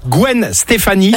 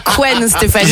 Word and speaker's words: Gwen Stefani: Bon, qu'est-ce qu'on fait, Selena Gomez, on va se faire plaisir également en playlist 2000 avec, je le dis bien cette fois-ci, parce que sinon, je vais me faire reprendre de voler Gwen Stefani. – Et non pas Gwen Stefani.